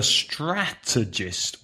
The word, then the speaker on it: strategist: So a strategist. Also three syllables. Stress the first syllable. Strategist. A strategist uses strategy.